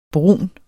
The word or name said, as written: brun